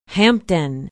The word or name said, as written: hampden